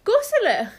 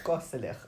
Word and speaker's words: koselig: The Norwegian word for cozy, which I learned from dear friend of the pod, Andy, is koselig. – Goselich. – Goselich. I think that's how you pronounce it.